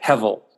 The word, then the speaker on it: hevel: Yeah, it's a Hebrew word. I pronounce it hevel.